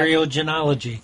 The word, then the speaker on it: theriogenology: How do you pronounce that? – Theriogenology. – Theriogenology. – That's real close.